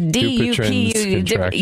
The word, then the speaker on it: Dupuytren's contracture: I don't know how to say it correctly either. – It's Dupuytren's contracture. Yeah, Dupuytren's, yeah. Contracture, which is, and had surgery in both hands.